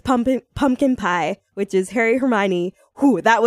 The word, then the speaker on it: Hermione: Hermione's name wrong.